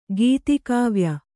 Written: ಗೀತಿಕಾವ್ಯ

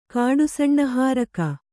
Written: ಕಾಡುಸಣ್ಣಹಾರಕ

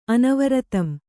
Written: ಅನವರತಂ

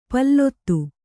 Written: ಪೊಲದಿ

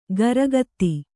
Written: ಖುರಗತಿ

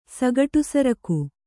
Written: ಸಗಟುಸರಕು

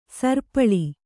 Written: ಸರಿಪಾಲು

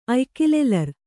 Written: ಅಯ್ಕಿಲೆಲರ್

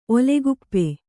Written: ಓಲೆಕೊಪ್ಪು

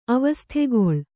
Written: ಅವಸ್ಥೆಗೊಳ್

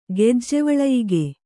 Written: ಗೆಜ್ಜೆವಳಯಿಗೆ

